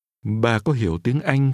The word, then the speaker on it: Tiếng Anh: Tiếng Anh. Tiếng. – Language is pronounced with a high rising tone.